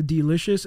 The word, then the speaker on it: Delishus: The Delish-Us brand. The Delicious. It's spelled D-E-L-I-S-H-U-S.